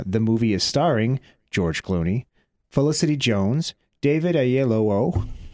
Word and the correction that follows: Oyelowo: Did I say his name right? – Uh, Oyelowo? – Yeah. Oyelowo. Sorry, David.